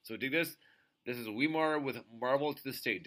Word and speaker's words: Weimar: So, next up, we have another great band, a band called, I believe it's pronounced Weimar, so W-E-I-M-A-R.